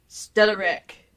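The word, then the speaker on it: Stellariq: Stellaric. – Did you just break your Volvo? No. Or is that how it's actually pronounced? – That's actually how it's spelled, pronounced. Okay. S-T-E-double-L-A-R-I-Q.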